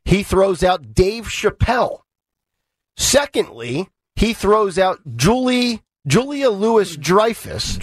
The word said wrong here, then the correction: Louis-Dreyfus: Julia Louis-Dreyfus. – Okay. It says Lewis here, L-O-U-I-S. – Yeah, it's pronounced.